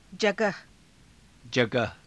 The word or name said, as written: jagaha